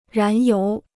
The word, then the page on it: 燃油: rán yóu